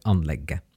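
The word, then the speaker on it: anlegget: So you write with a T, but you pronounce it with a silent T, like anlegge.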